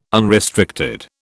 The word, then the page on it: unrestricted: British English